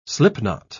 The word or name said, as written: slipknot